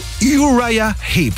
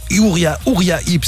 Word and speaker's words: Uriah Heep: Uriah Heep, c'est comme ça que ça se prononce. On peut dire Uriah Heep si vous préférez, mais Uriah Heep au Cirque Royal de Bruxelles, ça va sonner un petit peu comme ça.